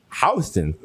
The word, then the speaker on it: Houston: House Street's pronounced Houston, not Houston.